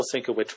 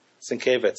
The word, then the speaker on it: Sienkiewicz: Did I pronounce his name right correctly there? Bill Sienkiewicz? I know you're a huge fan. Am I getting that right? Sienkiewicz. So I'm not even close. That's how is Bill Sienkiewicz's name pronounced is probably the next urban legend that we'll see on this blog. – I think it's Sienkiewicz.